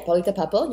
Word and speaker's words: Paulita Papel: So I'm, yeah, Paulita Papel, you pronounce it right.